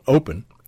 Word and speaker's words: Opn: One of the interesting ones that I saw was a device called Open. It's spelled O-P-N, from a company called Oticon.